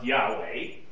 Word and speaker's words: Yahweh: When you translate I am, in Hebrew it's pronounced Yahweh, you translate that into Greek, you get ego eimi.